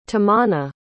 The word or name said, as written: tamanna